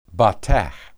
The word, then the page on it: batah: hebrew